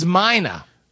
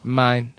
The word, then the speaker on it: Meine: It is Meine. We weren't sure how to pronounce his last name. – It's real subtle on that last syllable. – Meine.